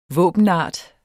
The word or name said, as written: våbenart